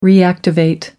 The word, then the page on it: reactivate: American English